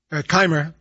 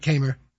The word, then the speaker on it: Kaymer: I was going to – I like you corrected the pronunciation and he said it again. Kaymer. Yeah, right, Kaymer.